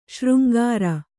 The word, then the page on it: ಶೃಂಗಾರಿ: śřngāri